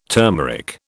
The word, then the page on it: turmeric: British English